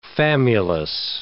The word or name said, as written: famulus